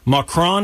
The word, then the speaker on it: Macron: Macron, is that how you say it? – Oh, yeah.